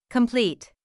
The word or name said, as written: complete